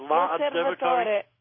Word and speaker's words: L'Osservatore: L'Osservatore.